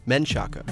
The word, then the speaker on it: Menchaca: That pronunciation is supposedly an anglicization of a Spanish surname, Menchaca.